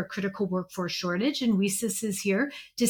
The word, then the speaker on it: WiCyS: So Women in Cybersecurity, we often go by our acronym, WICYS, and we pronounce it WICYS, like we sisters, because that's exactly what we are.